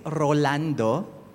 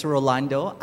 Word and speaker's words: Rolando: So my name is Rolando, and I usually emphasize the first syllable of my name because some people call me Orlando or Ronaldo. But it's Rolando.